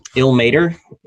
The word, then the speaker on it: Ilmater: Ilmater? Am I pronouncing that right? – Yeah.